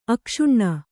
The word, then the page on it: ಅಕ್ಷುಣ್ಣ: kannada